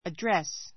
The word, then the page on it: address: ədrés; アドレス